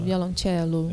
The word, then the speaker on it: violoncello: é violoncello que se pronuncia em italiano? Isso, violoncello.